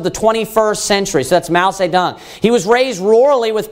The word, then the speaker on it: Mao Zedong: Mao Zedong, you can pronounce it. Sometimes it's pronounced in English. But Mao Zedong is how it's pronounced in Chinese, or in China.